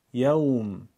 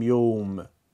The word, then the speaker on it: یوم: باید دقت کنیم اونو با لهجه عربی بگیم. یوم، نگیم یوم، با لهجه فارسی ادا نشه. مالک یوم الدین. بیت، نگیم بیت. بیت در زبان عربی، یوم بیت.